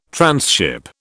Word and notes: transship: British English

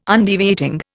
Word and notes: Undeviating: Indian

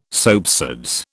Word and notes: soapsuds: British English

